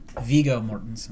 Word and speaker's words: Viggo Mortensen: And it actually starred, I always get this motherfucker's name wrong, is it Viggo Mortensen? – Viggo Mortensen.